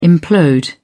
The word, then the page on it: implode: British English